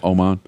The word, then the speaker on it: Oman: Is it Oman? Is that how you pronounce that country? – Yes. – Oman.